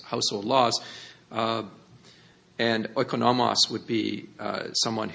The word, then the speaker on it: oikonomos: Now, you will find in some versions it might be translated as manager, but this is the word oikonomos. It's spelled O-I-K-O-N-O-M-O-S.